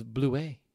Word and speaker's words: Blewett: By the way, Blewett is Blouet. It's French.